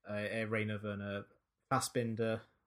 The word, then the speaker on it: Rainer Werner Fassbinder: But, you know, if you're going to compare him to someone else, I think he probably is closer to Rainer Werner Fassbinder. Wait, have I said his name right? I may have got his name wrong. – Yeah, that's him.